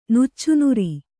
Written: ನುಚ್ಚುನೂರು

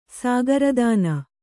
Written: ಸಾಗರದಾನ

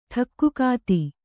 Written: ಠಕ್ಕುಕಾತಿ

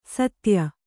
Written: ಸೀತ್ಯ

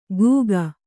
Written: ಘೂಕ

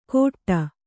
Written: ಕೋಟ್ಟ

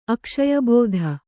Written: ಅಕ್ಷಯಬೋಧ

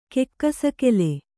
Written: ಕೆಕ್ಕಸಗೆಲೆ

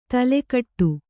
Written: ತಲೆಕಟ್ಟು

